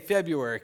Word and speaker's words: February: I, I feel like I feel like whoever came up with the word February was some really smart guy who's like, I'm just going to mess with people and put a silent R right in the middle.